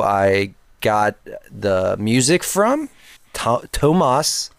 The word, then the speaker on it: Tomas: So the H is silent. – There is no H. – Oh, okay. So it's not silent. – It's T-O-M-A-S.